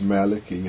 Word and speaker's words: Malachi: Malachi. It can be pronounced Malachi as well.